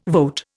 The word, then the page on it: vote: Indian